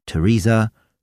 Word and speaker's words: Theresa: Außerdem muss man die Ausnahmen kennen. Als denglische Patienten brauchen wir uns nämlich gar nicht mit der Aussprache von TH abzumühen, wenn es ganz einfach wie ein T gesprochen wird, in Namen wie Theresa, Thomas, Thompson, Thames, Thai, Lesotho oder Thyme, dem Gewürz Thymian.